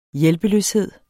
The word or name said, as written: hjælpeløshed